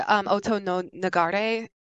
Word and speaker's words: Oto no Nagare: It's called Chimes of Dawn, and it's from, correct me if I say this wrong, Otto No Nagar. – Yeah, I say Oto no Nagare.